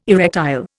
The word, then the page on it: erectile: Indian